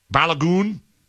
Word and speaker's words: Balogun: Is that how you say it? Balogun? – Balogun.